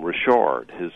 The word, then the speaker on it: Richard: And then Mark actually pronounces his last name Richard. His people come from Louisiana, and that's the way you say it.